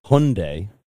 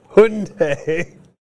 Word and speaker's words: Hyundai: There's three types of people. There's the people who know how to say the name properly with Hyundai. Mm-hmm. There's the people that call them Hyundai. And then there's the people that think they're Honda. – Hyundai.